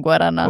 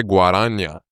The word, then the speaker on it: Guaraná: You only love saying Guaraná. – Well, because I finally know how to say it because I used to say Guaraña. – Yeah, you did say Guaraña.